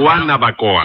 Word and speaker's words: Guanabacoa: Yo, porque no se dice Guenabacoa, sino Guanabacoa.